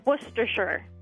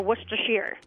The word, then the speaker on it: Worcestershire: Worcestershire or Worcestershire.